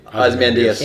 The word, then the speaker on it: Ozymandias: Ozymandias.